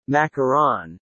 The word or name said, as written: macaron